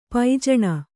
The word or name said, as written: ಪೈಜಣಿ